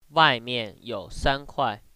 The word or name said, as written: uai